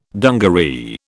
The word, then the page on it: dungaree: British English